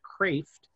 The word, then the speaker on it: Kreeft: Peter Kraft was, it's a Dutch name, so even though it sounds like Kreeft, it's pronounced Kraft.